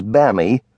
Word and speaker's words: Bammy: The oldest, Anna, was known as Bammy from Bambina and pronounced to rhyme with Sammy.